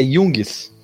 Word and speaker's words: Jungs: É, como é que fala o teu nome, cara? É Jungis. É O Thiago Jungis, que é da, da Coisinha Verde, né?